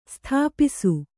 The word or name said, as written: ಸ್ಥಾಪಿಸು